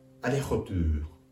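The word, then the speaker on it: aller-retour: フランス語で往復は、アレホトゥー。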